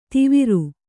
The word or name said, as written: ದೀವರು